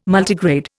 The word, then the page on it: Multigrade: Indian